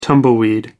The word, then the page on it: tumbleweed: American English